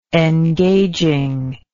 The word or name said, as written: engagingness